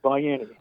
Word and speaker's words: Baiani: But well, you got the A after the B in Baiani. – Yeah. – So I get a lot of strange pronunciations, but that's correct. It's Baiani.